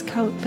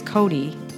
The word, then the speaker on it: Coté: Mrs. Coté, but that would be spelled differently, right? That's what I think, but... Or if you say your name is Coté, they'll spell it C-O-D-Y. C-O-D-Y.